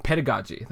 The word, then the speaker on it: pedagogy: We talked about pedagogy, that's the correct pronunciation of it.